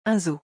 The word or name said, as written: Un zoo